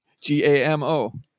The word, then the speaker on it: Gamo: G A M O.